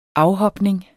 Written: afhopning

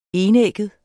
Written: enægget

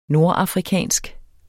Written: nordafrikansk

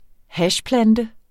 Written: hashplante

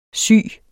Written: syg

